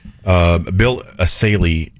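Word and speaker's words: Essayli: I've been told Bill Asaley is how you pronounce the name correctly, and I won't mispronounce it anymore.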